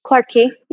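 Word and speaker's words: Clarke Quay: Clark Quay, yeah.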